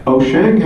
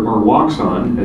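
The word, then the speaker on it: Wouxun: Osheng has or Waxon, as people pronounce it here in the US, has a 6 meter HD.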